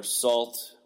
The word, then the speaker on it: Sault: The band is Sue, S-A-U-L-T. Sue, if you're pronouncing it the French way, or Salt, if you're pronouncing it some other way.